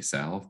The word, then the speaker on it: salve: The L is technically silent, but most people do say salve, but salve works too.